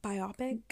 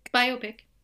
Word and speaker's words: biopic: the movie, the biopic with, did I say that correctly? Biopic? – Biopic. – Okay, yeah. Biopic. Don't come for me, anybody. – You said it right the first time.